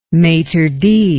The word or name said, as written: maitre d